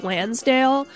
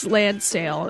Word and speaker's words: Land Sail: Right before that, from Japan, you heard P-Model with Missile from their album Land Sail, which I keep pronouncing in my head as Lansdale, but it's not. It's Land Sail, and it came out in 1980.